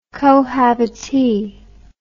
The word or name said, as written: cohabitee